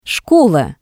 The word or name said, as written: shkola